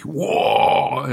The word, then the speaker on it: Warg: Ja, ich habe auch erst im Dawn of War 1 Intro gelernt, dass das eigentlich Warg ist. – Ja, das ist ein stummes G.